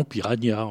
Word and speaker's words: piranha: Euh, le petit Robert euh, recommande na, le petit Larousse recommande nia, le hachette ne prend pas parti. Il y a des langues qui sont plus claires. En espagnol, il y a le n tilde, donc en espagnol, ça s'écrit vraiment piranha, en catalan aussi.